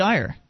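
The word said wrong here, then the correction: Eyre: Yeah, we had – or Air. It's pronounced Air. Pete Air.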